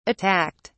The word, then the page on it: attacked: British English